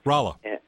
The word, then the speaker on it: Rolla: was it Rolla? – Rolla.